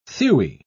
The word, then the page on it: thewy: British English